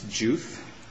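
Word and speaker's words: Diouf: How do you say it? Is it Joof? – It's Joof, like J-U-F. It's D-I-O-U-F, right? – Yeah, but it's pronounced Joof, like J-U-F.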